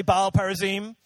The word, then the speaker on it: Baal Perazim: Say Baal Perazim. It's very good, good pronunciation.